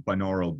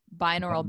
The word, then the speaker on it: binaural: So there are, for instance, there are apps with binaural beats,